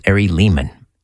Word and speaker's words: Ari Lehman: I'm told it rhymes with demon, so it's Ari Lehman.